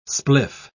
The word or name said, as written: spliff